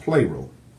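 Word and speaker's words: plero (πληρόω): It's spelled P-L-E-R-O-O. That's how it's spelled in Greek, but it's pronounced plero. Plero, okay?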